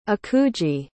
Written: akuji

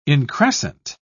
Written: increscent

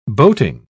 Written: boating

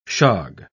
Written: shog

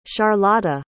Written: charlotta

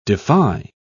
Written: defy

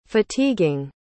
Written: fatiguing

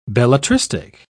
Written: belletristic

